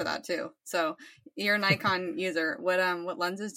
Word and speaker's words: Nikon: How do you say Nikon? – Very German, actually. Yeah. Nikon.